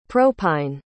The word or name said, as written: propine